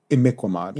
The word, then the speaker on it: Imiquimod: Imiquimod. – Yes, Yes, you can hardly spell it or say it. – Yes. – I-M-I-Q-U-I-M-O-D.